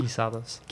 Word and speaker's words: Guisados: Guisados.